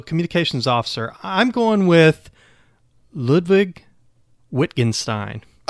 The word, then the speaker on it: Ludwig Wittgenstein: That's an excellent choice, but I have to correct your pronunciation. It's Ludwig Wittgenstein. – Okay, there we go. – Yep, in German the W's are pronounced like V's.